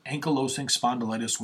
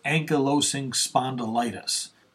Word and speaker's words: ankylosing spondylitis: Well, not really an article, but 29 things only someone with ankylosing spondylitis would understand.